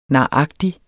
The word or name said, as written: naragtig